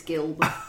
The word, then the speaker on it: Gilb: I'm Susie Q, or Susie Gilb, whatever, for those that don't know how to pronounce my fucking name. It's Gilb. – Just like it's spelled. – Right? – It's not Jill.